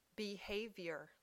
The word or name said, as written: behavior